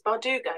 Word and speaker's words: Bardugo: Make sure I'm saying that right. I should know this off by heart because it's Bardugo, yes.